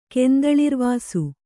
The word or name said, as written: ಕೆಂದಳಿರ್ವಾಸು